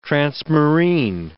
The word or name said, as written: transmarine